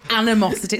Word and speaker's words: animosity: Lauren, it's pronounced animosity. It's like animal. – Think of animal and just then animosity.